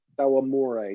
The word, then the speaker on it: Hirokazu Sawamura: the Japanese dude whose name I can neither remember nor pronounce. – Hirokazu Sawamura? – Sawamura, yeah, exactly.